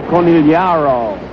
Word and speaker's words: Conigliaro: Tony Conigliaro. Or as Bob Sheppard says it, Conigliaro.